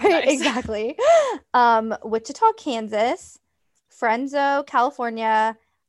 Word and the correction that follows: Fresno: You know, as I said that, I was like, ooh, that wasn't right. That was not right. Fresno, California.